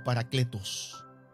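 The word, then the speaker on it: paracletos (παράκλητος): Por eso, querido, cuando Juan utiliza la expresión abogado tenemos, está usando una palabra que en el griego se pronuncia como paracletos.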